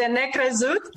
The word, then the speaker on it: necrezut: How do you pronounce it? I'll try it. – Was it the Necrezut? – There you go.